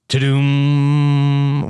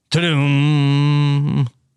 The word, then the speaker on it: Tudum: I watched a little show called Ta-Doom, which is all about, it's 2 hours of trailers for things coming out on Netflix. – What is it called? – Ta-doom. – And it's Ta-dum? Trailers. – How do you spell that, Rob? – T-U-D-U-M.